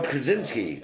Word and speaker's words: Krasinski: There you go, Krasinski. That's how you say it.